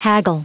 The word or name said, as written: haggle